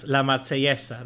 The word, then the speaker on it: La Marsellesa: Italy and France, La Marsellesa. That's how they say it.